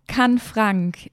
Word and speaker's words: Canfranc: Die WM in Canfranc. – Hast du schön ausgesprochen.